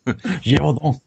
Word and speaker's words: Gévaudan: Gévaudan.